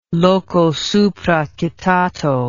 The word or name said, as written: loco supra citato